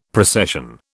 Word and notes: precession: British English

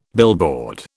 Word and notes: billboard: British English

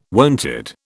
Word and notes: wonted: British English

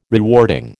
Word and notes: rewarding: American English

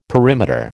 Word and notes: perimeter: American English

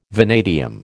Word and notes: vanadium: American English

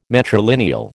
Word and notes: matrilineal: American English

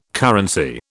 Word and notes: currency: British English

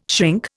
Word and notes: shrink: Indian

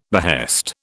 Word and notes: behest: British English

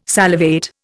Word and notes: salivate: Indian

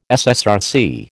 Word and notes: SSRC: American English

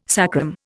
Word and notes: sacrum: Indian

